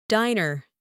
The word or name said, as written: diner